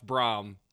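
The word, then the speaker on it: Brohm: It's pronounced Jeff Brom, like Tom. Thank you.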